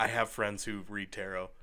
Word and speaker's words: tarot: Yeah, they need a certain accent to let you know this letter's silent, but they don't. – No, just guess. – But it's definitely tarot.